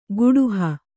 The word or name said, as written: ಕೂಡುಹ